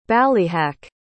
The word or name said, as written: ballyhack